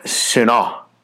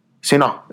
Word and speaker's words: sinah: It could refer to that, based on the Strong's definition here, of sinah. Sinah is quite literally the pronounced word.